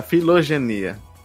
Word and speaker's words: filogenia: Aprendi uma palavra nova hoje: fisiogenia. Filogenia. Ih, falei errado já, filogenia.